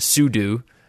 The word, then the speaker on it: sudo: And I never realized that people say do and sudo. At first, I thought he was saying it wrong, because I've always just said it sudo.